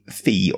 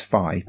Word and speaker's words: Fi: Yeah, I've been saying fee, but uh, apparently it's fi because it's to rhyme with words that are key to the game, such as high and sky and fly.